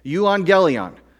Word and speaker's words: euangelion: euangelion,